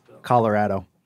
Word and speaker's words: Colorado: Colorado.